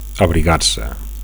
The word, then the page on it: abrigar: Catalan